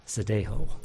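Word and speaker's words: zeteo: Zedeho would be easier to pronounce in Spanish. I'm going to pronounce it like it'd be pronounced in Spanish. Zedeho.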